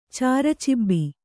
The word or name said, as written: ಚಾರಚಿಬ್ಬಿ